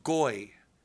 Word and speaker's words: goy: It's pronounced goy.